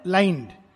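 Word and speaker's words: limned: word use किया लाइंड इसका pronunciation लाइंड है लेकिन इसकी स्पेलिंग है एल आई एम एन ई डी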